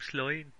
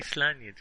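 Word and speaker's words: Sláine: Is that how you say Slay? I always say it like I'm from Birmingham, Sloin. But you say Slania, do you? – Slania. – I would say Slania, yeah.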